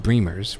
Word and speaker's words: Bremers: Uh, but here's the exciting thing about it is that Bremer's, uh, eh, her surname is pronounced Bremer's, rhymes with dreamers, even though it's B R E M E R S.